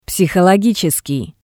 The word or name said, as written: Psihologitseskii